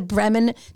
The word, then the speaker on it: Bremen: I'm not even sure if we say that correctly. B-R-E-M-E-N. Bremen.